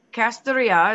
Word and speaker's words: Kastoria: So uh, Kastoria is how you say that one.